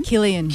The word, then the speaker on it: Cillian: Cillian.